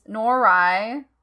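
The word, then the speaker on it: Noroi: But we are going to talk about this week's movie, which is Norai. I believe it's Norai because I looked it up and that's how it sounded for Google pronunciation.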